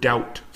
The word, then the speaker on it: doubt: And actually, on that note, bonus word, this isn't even included in the 13, but the word doubt, for example. D-O-U-B-T, duda. It's another same example where the B is silent, right? So it's a silent B.